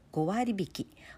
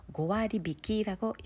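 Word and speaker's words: 고아리비키라고 (五割引き): 일본은 어, 한각 세일, 한각 세일 이렇게도 말하기도 하고 고와리비키, 고와리비키를 그대로 한자를 번역하면 오할 어, 뺀다 인자를 쓰는데요. 고아리비키라고 읽습니다.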